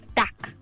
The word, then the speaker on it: 닭: Chicken in Korean is 닭. – 닭. – D-A-K.